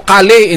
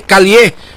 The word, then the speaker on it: Cali: Pero upon our verification, isa lang ka L, no? Single L lang. So Cali, hindi Calie. Mga kasama, man sa French, or sa French, sa Spanish, ang double L ginapronounce sina as Y, no? Calie.